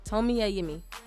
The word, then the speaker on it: Tomi Adeyemi: Tomi Adeyemi. Yes, I said it right this last time.